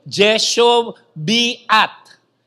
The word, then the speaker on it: Jehoshabeath: Ngayon, ito namang si J-E-H-O-S-H-A-B-E-A-T, ya, pwedeng Jehoshabeath, pero hindi. Kung kaya ninyo i-pronounce every syllable, yan ang tamang pronunciation. So, anong tamang pronunciation ng name niya sa Hebrew? Jehoshabeath.